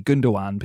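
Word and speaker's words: Gündoğan: You don't actually pronounce the second G, do you? It's like a silent G. Gundogan, people say.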